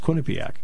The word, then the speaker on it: Quinnipiac: Right, it's Quinnipiac. – Okay. – Right, that's how you say it.